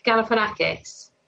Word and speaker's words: Galifianakis: But he looks like Zach Galifianakis.